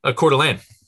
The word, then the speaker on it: Coeur d'Alene: Coeur d'Alene.